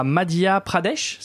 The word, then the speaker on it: Madhya Pradesh: Et il y a aussi l'hindi, l'hindi qui est très présent, par exemple, à Madhya Pradesh, c'est comme ça qu'on prononce En fait, oui, c'est comme ça qu'on prononce.